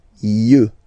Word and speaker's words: yeux: Un œil, des yeux, yeux, yeux. Okay, don't pronounce the final X as usual. Yeux, yeux, des yeux.